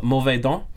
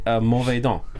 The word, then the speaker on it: mauvais dent: mauvais dent. Huh. It's French for saber tooth. – Yeah. – Well, it's a kitty. – It looks like it's spelled like mauvais dentes, but it's mauvais dent.